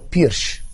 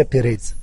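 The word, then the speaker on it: Pires: والنطق الصحيح لإسمي هو بيرش ليس بيريز، بيرش.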